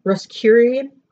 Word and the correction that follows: Rosicrucian: Rosicrucian, hmm, I knew I said it wrong, ritual and symbology.